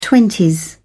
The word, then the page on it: twenties: British English